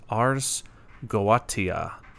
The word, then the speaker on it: ArsGoatia: I had to kind of look up the pronunciation of this. I guess it's based on a Latin phrase, but from what I can tell, it's pronounced ArsGoatia.